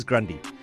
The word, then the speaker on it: Grande: Apparently the correct pronunciation is Grandi. Like Mother Grundy.